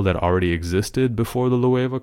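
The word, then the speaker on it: Loewe: Is it Le or Louave? – Louave, okay. This is the latest collab with Louave. I didn't even know it was pronounced like that.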